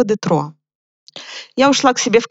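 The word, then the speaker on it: Détroit: И так до меня дошло, что слово «Детройт», город Детройт, по-французски это «Детро».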